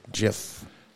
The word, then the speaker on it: GIF: Just GIF, like the peanut butter, GIF.